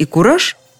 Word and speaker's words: кураж: И еще, слова «раж» и «кураж» подозрительно похожи. Они замечательно рифмуются. Может быть, они вообще однокоренные? Начнем с куража. Кстати, ударение именно такое на окончании. Кураж, куража, куражу и так далее.